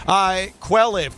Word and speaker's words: Quelliv: Quelliv, Q-U-E-L-L-I-V.